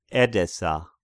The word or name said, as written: Edessa